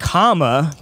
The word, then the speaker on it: Kamala: Because it's pronounced comma, but, you know, if you watch Trump speak, good luck trying to tell him what a comma is.